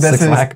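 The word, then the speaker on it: 6LACK: I still don't know how to say his name. Six Lack, apparently. – Black with a six.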